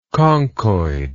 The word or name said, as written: conchoid